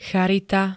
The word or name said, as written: charita